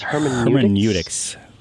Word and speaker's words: Hermeneutics: Hermeneutics.